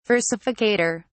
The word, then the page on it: versificator: British English